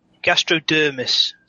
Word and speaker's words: gastrodermis: And then the Gastromonius. Did I say that right? – Gastrodermis. So gastrodermis. That's like gastro as in, you know, the gastrointestinal, and dermis as in skin.